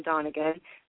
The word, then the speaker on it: Donegan: Dan Donegan? – Dan Donegan, yeah, exactly.